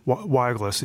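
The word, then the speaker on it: Wielgus: And you found this guy, Chuck Weilgus, is that how he pronounces his name? – Weilgus, yeah, Weilgus.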